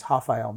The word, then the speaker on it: Rafael: His name is spelled Rafael, pronounced in Portuguese, Rafael Mendes.